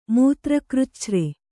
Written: ಮೂತ್ರಕೃಚ್ಛ್ರ

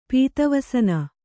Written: ಪೀತವಸನ

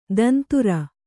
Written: ತಂತುರ

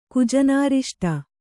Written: ಕುಜನಾರಿಷ್ಟ

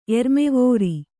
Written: ಎರ್ಮೆವೋರಿ

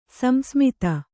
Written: ಸಂಸ್ಮಿತ

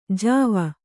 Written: ಜವ

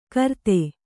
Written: ಘೃತ

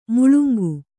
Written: ಮುಳುಂಗು

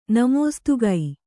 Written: ನಮೋಸ್ತುಗೈ

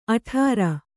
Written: ಅಠಾರಾ

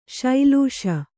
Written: ಶ್ಲೇಷೆ

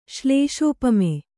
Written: ಶ್ಲೇಷೋಪಮೆ

